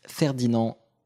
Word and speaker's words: Ferdinand: donc Friedrich Ferdinand Runge. – Ferdinand c'était nickel.